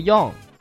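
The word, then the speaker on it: よん: 没错，虽然有的有两种说法，但是其中有一种是不常使用的，比如数字四，虽然有 yong、西两种读法，但是西与死同音，所以除表示月份之外，一般不读作西而读作 yong。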